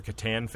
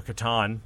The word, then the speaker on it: Catan: Mayfair does a lot of stuff there, so if you're a Catan fan, or Catan, depending on who you are and how you say it, there should be plenty of things going on with that, too.